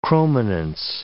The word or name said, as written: chrominance